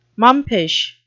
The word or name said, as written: mumpish